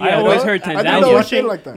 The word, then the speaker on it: Tanzania: And we were thinking of doing, actually, until recently, what's been happening there is it was one on our list, Tanzania. – Why do you say it like that? – That's how it's supposed to be said. – Oh, okay. – Is there another way of saying it? – Tanzania.